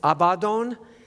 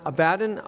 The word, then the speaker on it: Abaddon: Now the correct pronunciation is Abaddon, not Abaddon, Abaddon, which means destruction.